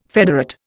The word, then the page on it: federate: Indian